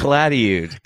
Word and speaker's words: Calatayud: Did I say that right? – Calatayud. – Calatayud. – Okay. – Yeah, Yud. Yud at the end.